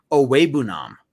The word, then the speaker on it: Okwuegbunam: Now, obviously, we've learned to say Okwuegbunam, but the announcer said that he told them he preferred they pronounce it Owebunam.